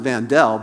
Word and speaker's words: Vandal: The family pronounced it Vandell, but it was spelled like the word vandal, which is perfect for football, right?